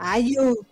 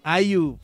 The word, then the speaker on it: IU: 아주 babe Repeat repeat 참 쉽지 right Yellow C-A-R-D y eso fue BB de IU. – ¿Lo pronuncié bien? – IU. – IU, demonios.